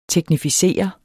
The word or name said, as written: teknificere